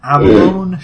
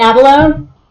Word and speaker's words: abalone: Next, an abalone shell holy symbol. – It's pronounced abalone? – I believe. A-B-A-L-O-N-E. – I knew how it was spelled. I always called it abalone.